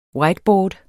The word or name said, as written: whiteboard